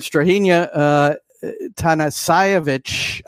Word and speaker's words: Strahinja Tanasijević: So we learned Strahinja Tanasijević, I think that's the way David Lee corrected me as far as the pronunciation, the defender, the Serbian, not here yet.